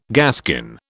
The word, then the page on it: gaskin: British English